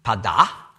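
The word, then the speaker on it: Pada (פדה): Also Vers 24, Pada, mit einem Kehllaut da unten, ganz im Althebräischen, ja, Pada. Und auf modernen Hebräisch spricht man das nicht mehr aus. Genau gleich wie das andere, das heißt Pada. Aber ohne diesen Kehllaut am Schluss.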